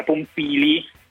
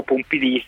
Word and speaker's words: Pompili: Barbara Pompili, o Pompili se lo vogliamo pronunciare alla francese, ministra dell'ecologia, ed ha un ruolo molto alto nel protocollo del governo.